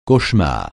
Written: cauchemar

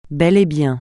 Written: bel et bien

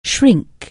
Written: shrink

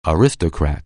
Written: aristocrat

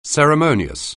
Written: ceremonious

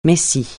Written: messie

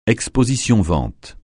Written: exposition-vente

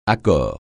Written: accord

